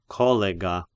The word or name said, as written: Collega